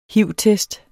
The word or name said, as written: hiv-test